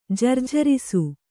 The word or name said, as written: ಜರ್ಜರಿಸು